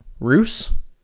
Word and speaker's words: Ruse: Climb aboard the Ruse. Spelled like Dr. Seuss, but with an R at the front.